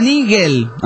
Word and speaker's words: Niguel: Dice: Niguel se pronuncia Nigel. Ah, Nigel, como Nigel Mansell, ¿no? Nigel. – Miguel. – No, Nigel. Nigel, no me aclara esto, que me lo separes en sílabas. – Claro, no me ayudas con eso. Se pronuncia Nigel, me dice. O Nigel, Nigel, sí, Nigel. Bueno, Laguna Nigel.